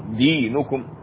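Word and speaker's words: دِينُكُمْ: الآية الأخيرة لكم دينكم ولي دين، لكم دينكم، دينكم انتبه لضم النون هنا، دينكم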